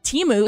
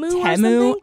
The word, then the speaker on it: Temu: Temu. It was crazy. Also, we've been saying the name wrong. It's like Temu.